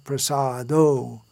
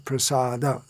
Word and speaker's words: prasada: Just as a side note, pretty much everyone in Mangalarti, those who attend Mangalarti, sing chaturvidha Sri Bhagavat prasado. But it should be actually chaturvidha Sri Bhagavat prasada. Look in the songbook and see how it should be pronounced.